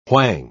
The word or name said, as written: whang